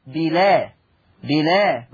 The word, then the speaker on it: بالأسحار: أولا عندنا في هذا اللفظ بالآ هذا اللفظ فيه النقل، أي تنطق لام مفتوحة هكذا بلا بلا.